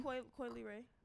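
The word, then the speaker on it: Coi Leray: Is that how you pronounce her name? – Dej Loaf? – No. – Yeah, you said it right. Coily Rae.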